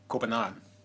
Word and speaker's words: Copenhagen: Isn't it Copenhagen? – Yeah, that was really well done.